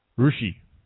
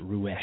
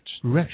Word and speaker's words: Roueche: R-O-E-C-H-E. – Nope. – Oh. – R-O-U-E-C-H-E. – Correct. – Yeah. Rushi. – Hmm. I think they pronounce it Ruesh in French. – Ruesh.